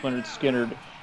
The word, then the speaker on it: Lynyrd Skynyrd: Yeah, so this one was originally on the debut album, pronounced Lynyrd Skynyrd.